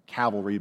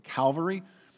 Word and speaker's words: Calvary: Not cavalry, but Calvary.